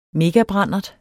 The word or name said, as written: megabrandert